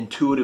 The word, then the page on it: intuitive: American English